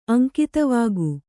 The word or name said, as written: ಅಂಕಿತವಾಗು